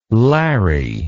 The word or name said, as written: Larry